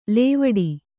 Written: ಲವಡ

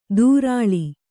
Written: ದೂರಾಳಿ

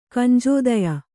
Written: ಕಂಜೋದಯ

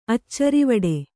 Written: ಅಚ್ಚರಿವಡೆ

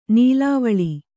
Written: ನುಲುವಾಲೆ

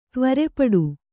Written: ತ್ವರೆಪಡು

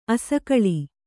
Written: ಅಸಕಳಿ

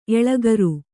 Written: ಎಳಗುರಿ

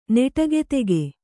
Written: ನಟಿಕೆತೆಗೆ

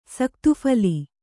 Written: ಸಕ್ತುಫಲೆ

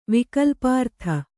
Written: ವಿಕಲ್ಪಾರ್ಥ

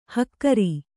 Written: ಹಕಾರ

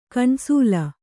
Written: ಕಣ್ಸೋಲ್

